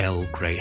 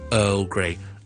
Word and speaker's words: Earl Grey: 他因为成日听到啲人读 Earl Grey，不是 Earl Grey，是 Earl Grey, Earl。